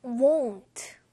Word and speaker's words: won't: 자, won't. 요 발음이 조금 어려울 수 있는데요. won't. want, 원하다 할 때 want랑 분명히 조금 다르게 들릴 겁니다. want. 요거랑 won't. 분명히 다르죠. 얘가 조금 더 오 하고 입이 모아지는 발음입니다.